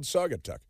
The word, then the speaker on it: Saugatuck: It's actually called Saugatuck. I'm not mispronouncing it. That's what it's called.